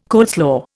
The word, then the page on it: coleslaw: Indian